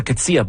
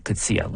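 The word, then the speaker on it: Coetzee: Luca Cuzzia, by the way, I should tell you how she spells her last name. It's C-O-E-T-Z-E-E. And she pronounces it Cuzzia. Luca Cuzzia, the cellist from Calgary, and you're hearing her here on the In Concert Spotlight this afternoon on CBC Music.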